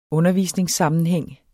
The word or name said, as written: undervisningssammenhæng